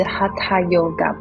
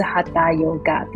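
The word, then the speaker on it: Hatha yoga: 那他在教导我们的时候，他跟我们分享这个哈塔哈塔 yoga，他其实他发的音是哈塔 yoga，不是哈达 yoga 的这个这个发音哦，所以我们才在翻译上就是把它是写成跟原文比较贴近的一个翻译，所以我们就叫它哈塔瑜伽之光。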